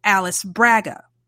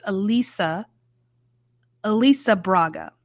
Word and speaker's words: Alice: So when I look at her name, you know, just as it's written, my brain wants to phonetically pronounce that Alice Braga. That's not right. Apparently it's Elisa Braga, which is beautiful.